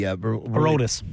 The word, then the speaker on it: Aroldis: So, how do you pronounce his first name? Chapman, the Aroldis. – Aroldis, yeah.